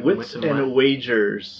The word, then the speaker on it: Wits and Wagers: Wits and Wagers. – Waggers. – You're probably mispronouncing it, Sam.